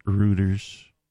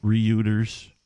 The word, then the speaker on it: Reuters: Speaking of Musk, this from Reuters, or some people pronounce it Reuters. – They're wrong. – Reuters.